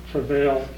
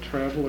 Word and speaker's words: travail: And Paul says that the whole creation groans in travail, waiting. How do you say that, travel or travail? – Travail. – Okay. And I had one, somebody tell me once it was travel, and I've always had trouble, ever since then, I've had trouble with that.